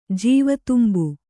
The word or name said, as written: ಜೀವತುಂಬು